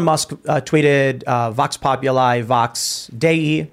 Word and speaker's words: Dei: DEI means God. Of God. That's creepy to me. – Yeah. Apparently pronounced Dei, by the way.